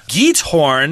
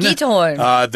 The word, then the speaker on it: Giethoorn: If you want to see small towns in the Netherlands, you could always pay a visit to the village of, and how are we saying this? – Giethoorn. – Giethoorn. – That's what I looked up on the internet. There were a couple of different sites that gave different pronunciations, but I'm going to go with that, Giethoorn.